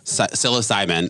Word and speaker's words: psilocybin: Psychosilibin. What is it called? Did I say that right? psilocybin, psilocybin, mm-hmm.